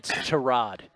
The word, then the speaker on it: Tyrod: It's Tyrod. It rhymes with Todd, by the way.